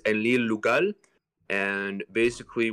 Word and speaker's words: Enlil: So this sign represents the syllable ein, this one represents the syllable lil, and so you read it as enlil, mm-hmm.